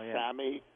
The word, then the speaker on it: SAMe: But basically, there was a sale at the health food store I go to on, it's spelled S-A-M-E, but pronounced Sammy.